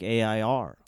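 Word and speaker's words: Eyre: That's E-Y-R-E, but it's pronounced like A-I-R.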